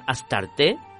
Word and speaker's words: Astarte: Astarte, la diosa Istar de los textos de Mesopotamia, en griego se decía Astarte, tal como lo pronunciamos en castellano.